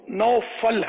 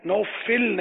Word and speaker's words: نوفل: تو ان صحیح تلفظ اس کا انہوں نے نکالا لغت سے تو نوفل ہے, نوفل نہیں ہے.